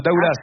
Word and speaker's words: Daoulas: Vous habitez Daoulas. – Oui, c'est ça. – Et on prononce bien le S à la fin de Daoulas.